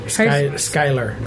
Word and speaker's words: Skyler: So it's Shire Rumsey? – Skylar. – Skylar? That does not look like what it's spelled as. – Yeah, spell it S-K-Y-L-E-R. That's how it's pronounced. – Oh, the one that I first saw, I thought was pronounced Shulier or something.